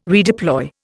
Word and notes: redeploy: Indian

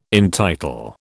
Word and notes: entitle: British English

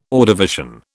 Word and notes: Ordovician: British English

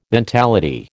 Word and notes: mentality: American English

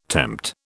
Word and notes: tempt: British English

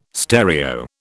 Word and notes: stereo: British English

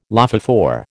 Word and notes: lophophore: American English

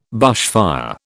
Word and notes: bushfire: British English

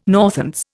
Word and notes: Northants: Indian

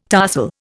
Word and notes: tarsal: Indian

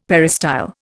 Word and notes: peristyle: Indian

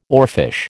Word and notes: oarfish: American English

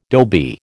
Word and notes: dhobi: American English

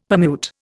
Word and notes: permute: Indian

